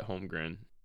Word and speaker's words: Holmgren: And he wants, I think everyone in the country wants Chet Holmgren.